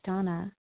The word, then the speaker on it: Dana: This is Donna B., recovered compulsive overeater and bulimic, calling from Denver, Colorado. I do spell my name D-A-N-A, although it's pronounced Donna.